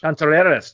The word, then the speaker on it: Contreras: Contreras, there you go. You got to have the twang. – Roll the R.